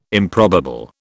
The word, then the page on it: improbable: British English